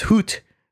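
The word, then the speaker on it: Hütte: The Hoot restaurant, I think it's pronounced Hoot, H-U-T-T-E, with the two dots, the umlaut over the U. I think it's Hoot. That'd be my guess.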